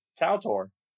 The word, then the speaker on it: KOTOR: My refutation of that AI-generated noise right there is, how do you pronounce the acronym for Knights of the Old Republic? – Kowtor.